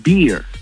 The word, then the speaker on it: beer: Porque con, o sea, la barba se pronuncia beard, el oso se pronuncia bear, ¿eh? Y la cerveza se pronuncia beer. – ¿Eh? – Pero con R al final.